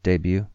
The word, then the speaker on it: debut: The T in debut is silent.